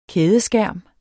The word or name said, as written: kædeskærm